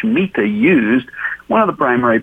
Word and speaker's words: Shemitah: And that word remission is the word Shemitah. By the way, that's spelled S-H-E-M-I-T-A-H, Shemitah.